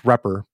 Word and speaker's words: repr: the one that is pronounced repr, but it's double underscore R-E-P-R double underscore.